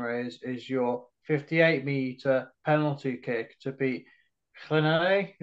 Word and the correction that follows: Llanelli: Is that you pronounce it? – Llanelli. Yeah, it's difficult.